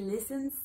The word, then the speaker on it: listen: Listen se escribe con esta T acá, ¿verdad? La escribimos, pero no la pronunciamos. Es muda.